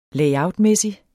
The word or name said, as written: layout